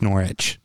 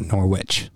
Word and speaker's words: Norwich: It's Norwich, not Norwich.